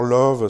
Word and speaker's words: Lauw: Alors Love, ça s'écrit pas L-O-V-E, euh, s'écrit L-A-U-W,